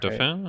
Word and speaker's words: Dauphin: I think this episode is called The Dauphine. I hope I'm pronouncing that Dauphin? – Right. Dauphin, yeah.